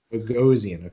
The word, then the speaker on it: Bogosian: Bogosian, okay.